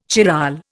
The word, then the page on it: chiral: Indian